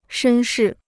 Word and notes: 绅士: shēn shì

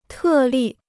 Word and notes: 特例: tè lì

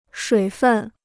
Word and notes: 水分: shuǐ fèn

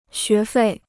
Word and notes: 学费: xué fèi

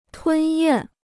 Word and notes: 吞咽: tūn yàn

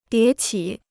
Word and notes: 迭起: dié qǐ

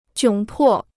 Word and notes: 窘迫: jiǒng pò

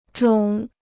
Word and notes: 肿: zhǒng

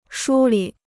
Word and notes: 梳理: shū lǐ

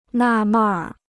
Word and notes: 纳闷儿: nà mèn r

